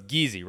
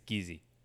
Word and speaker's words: Giese: So it's Giese, right? It's not Giese. It's not Geise. It's not Geist. It's Giese.